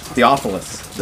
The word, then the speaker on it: Theophilus: Dear Theophilus, did I pronounce that correctly? – Theophilus.